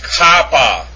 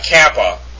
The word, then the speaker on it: kappa: The next is kappa. Not kappa. Kappa.